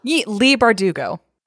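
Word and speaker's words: Leigh Bardugo: Leah Bardugo? – Lee Bardugo. I've read several of her books.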